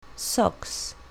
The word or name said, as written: socks